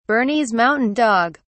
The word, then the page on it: bernese mountain dog: British English